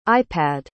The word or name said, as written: ipad